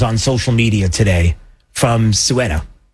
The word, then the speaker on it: Ceuta: Okay, I got to say it right. I've been saying Quetta. I've been saying Ceuta. Ceuta. I apologize.